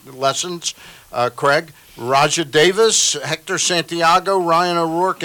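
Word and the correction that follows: Rajai: Rajay Davis.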